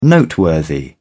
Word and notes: noteworthy: British English

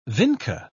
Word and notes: vinca: British English

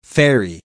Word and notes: ferry: American English

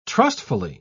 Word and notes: trustfully: British English

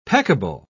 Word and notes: peccable: British English

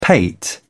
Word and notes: pate: British English

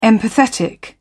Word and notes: empathetic: British English

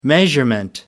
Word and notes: measurement: American English

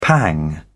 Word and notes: pang: British English

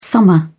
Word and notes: summer: British English

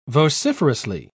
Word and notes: vociferously: British English

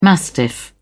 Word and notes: mastiff: British English

